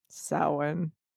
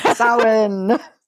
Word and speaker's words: Samhain: But suffice it to say, some people actually do pronounce it Samhain. And I guess they said, whatever article I had come across, they said it was like regionally based, like wherever you are, wherever they're pronouncing it, however that is, it is right for you.